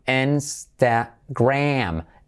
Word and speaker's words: Instagram: ¿Cómo se pronuncia Instagram? Instagram. Insta y como es. Letra clave R, Instagram. Y la, esa A casi como una E. Instagram. Instagram. Y el acento es como la N.